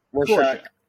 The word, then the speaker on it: Rorschach: Rorschach.